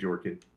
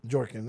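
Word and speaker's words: Bjorkgren: I say Jorkin. – Jorkin. That's what it is. I think the B is silent, but you learn.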